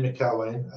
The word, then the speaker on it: McElwaine: Yeah, my name is Andy McElwaine, and from Sheffield originally, but live down in the big city of London these days.